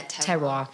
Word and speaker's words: terroir: I'm probably not pronouncing it right. Is it terroir? – Yeah, terroir.